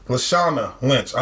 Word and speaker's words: Lashana Lynch: Latasha... Lashana Lynch. I'm sorry. I butchered that.